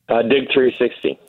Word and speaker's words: DIG 360: DIG 360.